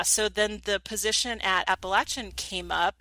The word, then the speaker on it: Appalachian: I have to keep reminding myself to pronounce it correctly because I'm like very like, Arizona girl going Appalachian.